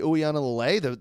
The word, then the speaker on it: Uiagalelei: And I love DJ Uiagalelei, the backup quarterback. He's going to be an absolute freak show. Did you like that pronunciation? – Dude, you nailed it.